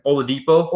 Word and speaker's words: Oladipo: And then they got Oladipo. I hadn't seen. I can't pronounce his name. – Oladipo.